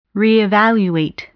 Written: reevaluate

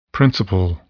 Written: principle

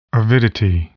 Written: avidity

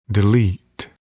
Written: delete

